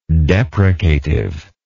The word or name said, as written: deprecative